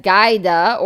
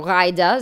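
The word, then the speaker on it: Ghaida: Meretz member of Knesset Gaida, or Raida Zoabi, it's spelled with a G, but it's actually pronounced with an R, so Raida Zoabi,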